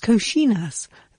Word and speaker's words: coxinhas: Introducing COXINHAS. Pronounced Cochinas, these little croquettes stuffed with chicken and spices are popular snacks served in bars all over Brazil.